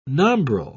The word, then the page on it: nombril: British English